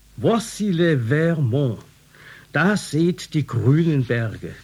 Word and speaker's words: Vermont: Daher hat Vermont seinen Namen und deshalb wird er auf der zweiten Silbe betont, nicht auf der ersten, wie es im Englischen korrekt wäre.